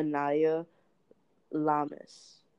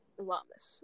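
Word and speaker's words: Inayah Lamis: Anaya Lamas. – Lamas. So you guys heard it. It's been stamped. It's been approved.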